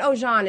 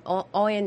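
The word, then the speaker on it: Oyin: No, not Oyen. It's O-I-Y-N. Oyen Natural. It's basically started by this woman, her and her husband, mm-hmm.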